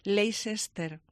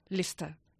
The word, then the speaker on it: Leicester: Y nosotros, con muy buena intención, nosotros quiero decir los españoles, lo intentamos pronunciar bien y tendemos muchísima tendencia a decir Leicester. – ¿Y cómo se pronuncia? – Leicester.